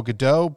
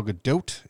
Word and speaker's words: Gadot: So they, they say Gal Gadot, but it's actually Gal Gadot. It's uh, ah, it's a Gadot. – Hebrew word. It's not French. It's Hebrew. So the T is pronounced Gal Gadot.